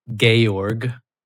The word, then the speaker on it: Georg: First name, Georg. – Georg? That's one word. – G-E-O-R-G. That's how you pronounce George in German.